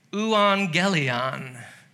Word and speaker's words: euangelion: Gospel in Greek is pronounced euangelion.